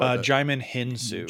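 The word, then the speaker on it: Djimon Hounsou: But to drop back and have Lupita Nyong'o and Digimon Hewson, I know I pronounced his name wrong. I feel terrible. – Jaimin Hinsu. Jaimin Hinsu. If I'm remembering correctly.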